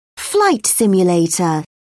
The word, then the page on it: flight simulator: British English